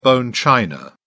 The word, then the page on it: bone china: British English